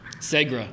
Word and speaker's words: Segra: First of all, how do we pronounce the stadium's name? – Segra. I figured it Segra. – Out. – Segra. – I figured it out. – Segra Stadium.